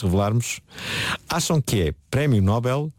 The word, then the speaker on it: Nobel: Nós quando pronunciamos, por exemplo, papel e anel, nós pomos a sílaba tónica na segunda sílaba. Nobel. Nobel, Noel. E Nobel é exatamente a mesma coisa. Além disso... E a principal razão é que o nome do fundador deste prémio chama-se Nobel.